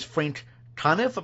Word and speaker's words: Conniff: You had on for the first part Trace Ballou from Mystery Science Theater 2000, and then part two is Frank Conniff. Am I saying that right? – Yep, saying it right.